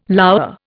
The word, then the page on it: lour: Indian